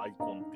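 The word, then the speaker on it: 眼魂: うんうんうん、だから、えっ、と、ガンコンって書いてるんですけど、読み方はアイコンっていうことになりますね。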